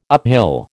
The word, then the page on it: uphill: American English